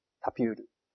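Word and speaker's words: Tapur: けじゃなくて、自分が聞くために、録音させてもらっていいですかって言って、こう、録音させてもらうといいと思います。で、スカイプの録音についてもまた質問が来るかなと思うんで言っとくんですけども、僕自身はスカイプでこう録音するときに、タピュールっていう録音ソフトを使ってます。タピュール。タピュール。綴り言いますね。 T、東京の T に、 A、 ABC の A ですよね。に、 P、に、 U、に、 R。で、タピュールと読みます。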